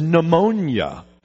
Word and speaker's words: pneumonia: such as pneumonia. Now, we don't pronounce the P. We don't say pneumonia.